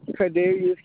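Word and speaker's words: Kadarius: Cardarius Campbell.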